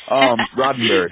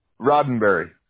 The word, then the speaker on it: Roddenberry: Roddenberry. – For real. I'm sorry? – Roddenberry. – Okay. How do you spell that? – R-O-D-D. – Okay. – E-N-B-E-R-R-Y.